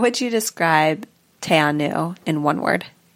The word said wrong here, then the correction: Te Anau: Oh, shoot. I said it wrong. How would you describe Tianu in one word? – Majestic.